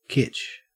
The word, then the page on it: kitsch: Australian English